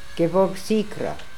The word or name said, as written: Gevorgsikra